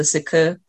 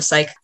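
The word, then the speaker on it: Cike: or I think it's technically supposed to be pronounced the Sikku, but we'll just call it the psych. That's how they pronounce it in the audiobook, so at least the audio people will understand.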